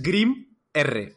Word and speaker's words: Grimr: Ojo a la pronunciación, ¿eh? La saga de Grimbr. yo, yo, te he escuchado, pero no sabría cómo escribirlo. bueno, pues es Grim R. Grimr. ¿Vale? – ¿Por qué traigo este cómic?